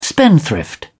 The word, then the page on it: spendthrift: British English